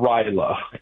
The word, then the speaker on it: Rajala: It's called Ryla, and the it's spelled R-A-J-A-L-A.